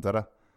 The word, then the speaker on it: Zara: Und dann Zada, Zada, weil der Spanisch heißt ja nicht Zara, sondern Zada. Das muss man alles richtig aussprechen.